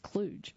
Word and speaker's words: kludge: Twyla, in the chapter titled Build a Second Act, I talk about kludge, a word techies use for a short-term fix or workaround. Excuse me, that is pronounced kludge.